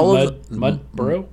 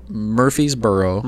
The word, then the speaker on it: Murfreesboro: How do you say it again? Mudboro? – Murfreesboro.